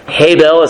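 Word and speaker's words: Habel (הבל): Habel is how you pronounce it in the Hebrew.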